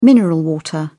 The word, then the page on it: mineral water: British English